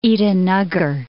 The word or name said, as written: Itanagar